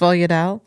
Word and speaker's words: sfogliatelle: Sfogliatelle. – Yes. – Sfogliatelle.